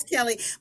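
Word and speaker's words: Kelle: It's Kelly.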